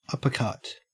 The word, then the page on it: uppercut: Australian English